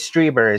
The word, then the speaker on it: Strieber: And it is Strieber is how you pronounce it.